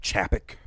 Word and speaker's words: Chapek: There was an investor call this week where, you know, Bob Chapek, I believe is how you say his name. – Yes.